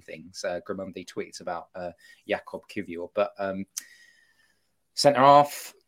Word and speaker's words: Kivior: Jakob Kivior. I think I've said it right. – Well done. – Do you like it? I like the fact you're going with phonetics. Talk to me about Jakob Livior. – Kivior.